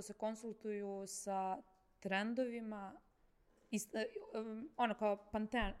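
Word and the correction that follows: Pantone: kako se zove boje koje izbacu svake godine? Sa pan... – Panton. Panton. – E, dobro, ne znam da izgovorim.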